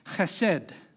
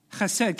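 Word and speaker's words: chesed (חסד): Steadfast love is one word in Hebrew, and it is spelt like this: chesed. Chesed, can you say that? No, I want to hear the ha. Chesed, yes, thank you.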